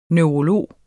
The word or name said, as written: neurolog